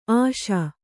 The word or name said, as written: ಆಶಿ